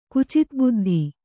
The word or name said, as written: ಕುಚಿತಬುದ್ಧಿ